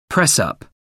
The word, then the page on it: press up: American English